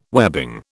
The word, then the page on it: webbing: British English